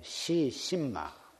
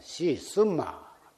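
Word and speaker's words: 시 쓴마 (是甚麼): 그러나 산승이 오늘 여러분에게 일러드리고자 한 화두는 오리 꺼내는 화두가 아니고 이 무엇고 한문으로는 시 심마 중국 발음으로는 시 쓴마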